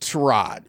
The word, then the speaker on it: Tyrod: I'm sorry, Tyrod. His name is pronounced Tyrod. We need to start doing that more correctly.